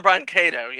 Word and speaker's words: Brancato: So a new story was written for Canon by Ted Newsome and John Brank. I hope I pronounce this right. John Brancato? John Brank? – John Brancato, yes.